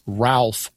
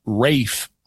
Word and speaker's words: Ralph: You'd say it as an American. His name is Ralph Rackstraw. But if you watch performances, they use British pronunciation and often call him Rafe Rackstraw.